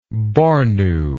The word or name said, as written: Bornu